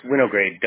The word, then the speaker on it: Winograde: It's Winograde, W-I-N-O-G-R-A-D-E. – Got it. Thank you. It sounds exactly how it's spelled, right?